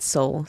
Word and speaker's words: Seoul: Yes, in English, if I pronounce it like that, it rhymes. But it sounds the same. It's Seoul, my soul.